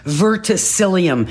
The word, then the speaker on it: verticillium: Verticillium. – Did I say that right? – No, pardon me? – Oh yeah, no, it's actually pronounced verticillium.